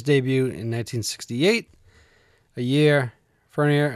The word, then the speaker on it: Fournier: His first name is Vincent, and I can never pronounce his last name correctly. It's like Fournier. – Yep. Hey! Vincent Damon Fournier.